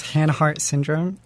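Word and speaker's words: Haanhart syndrome: It is Hanhart syndrome, H-A-A-N, heart syndrome.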